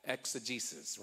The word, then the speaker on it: exegesis: So so we're trying to break it down and give you, that's called exegesis, right? Did I say it right? – You said it right.